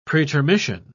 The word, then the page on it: pretermission: British English